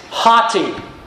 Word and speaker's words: hoti: The Greek word that begins verse 16 is hoti. In English, you can spell that H-O-T-I. Hoti. And